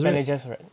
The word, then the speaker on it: Bene Gesserit: Bene Gesserit, yeah.